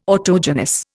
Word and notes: autogenous: Indian

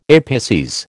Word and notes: apices: American English

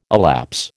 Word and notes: elapse: American English

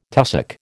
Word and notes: tussock: American English

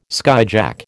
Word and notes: skyjack: American English

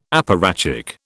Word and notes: apparatchik: British English

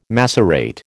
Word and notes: macerate: American English

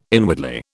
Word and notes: inwardly: British English

